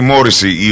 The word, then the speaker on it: Morrissey: Ο Στίβεν Πάτρικ Morrissey, ή Morrissey η ορθή προφορά του ονόματός του, το επώνυμο του συγγνώμη, γεννήθηκε σαν σήμερα το 1959 στο Μάντσεστερ της Αγγλίας από γονείς Ιρλανδούς μετανάστες.